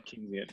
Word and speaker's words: Keynesian: And I guess I could see that as being like a potentiality for something like, is it Keynesian economics? Is that how you pronounce it? – Yep. – Keynesian.